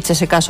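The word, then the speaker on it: Τσεσεκά (ЦСКА): Τον αντικαταστάτη του Μπαρίνοφ βρήκε η Τάσκα Μόσχα σε περίπτωση αποχώρησής του, όπως τονίζουν οι Ρώσοι. Συγγνώμη, η Τσεσεκά Μόσχα βέβαια.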